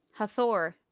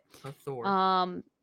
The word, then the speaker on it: Hathor: Hathor, I think Hathor. That's how you pronounce it. – Hathor.